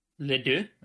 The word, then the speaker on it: Les Deux: Yeah, don't pronounce the S. – Les deux? – Les deux.